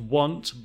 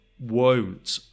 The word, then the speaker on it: won't: Notice the pronunciation, not want, but won't.